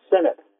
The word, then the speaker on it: Sinot: It's close. It's Sinot. – Sinot. Oh, I could have gone either way. Okay. I did the hard eye, but I'm wrong.